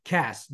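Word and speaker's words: Qass: You have VJ and Quass, I guess that's how you say it, Singh, who finally won it last. – Cass, what am I? I'm an idiot. Cass, there's no Q.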